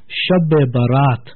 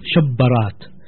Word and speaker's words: Shab-e-Barat: Now, the original word is Shab-e-Barat. Now, because we are Indian origin, so we pronounce the word Shabbarat.